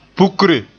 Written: pukre

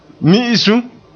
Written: mi'isum

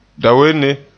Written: dawenne

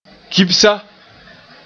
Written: kibsa

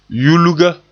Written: yulga

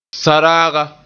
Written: saraaga

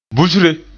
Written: busre

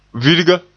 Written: virga

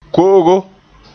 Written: ko'ogo

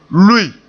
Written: lui

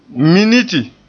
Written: miniti

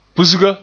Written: pusga

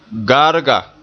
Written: gaarga

